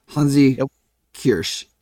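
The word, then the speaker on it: Hansi Kürsch: Yeah, it's for those listening, it's H-A-N-S-I, then K-U with an umlaut, R-S-C-H, Hansi Kirsch.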